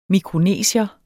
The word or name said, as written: mikronesier